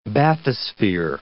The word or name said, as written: bathysphere